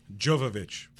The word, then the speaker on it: Jovovich: Jovovich.